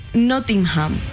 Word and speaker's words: Nottingham: Este equipo, no sé cómo se dice, jugador de Nottingham.